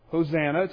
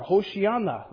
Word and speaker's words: Hoshiana: And the crowds that went ahead of him and those that followed shouted, Hosanna to the Son of David, or Hoshiana. That's how you say it in Hebrew.